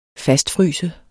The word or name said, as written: fastfryse